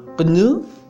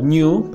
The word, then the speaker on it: GNU: Stallman menciona que se debe de se pronuncia como GNU en inglés o New para los que hablamos en español. De hecho, lo menciona en dos o tres idiomas como sería la forma correcta de pronunciación, la cual significa G, no es Unix.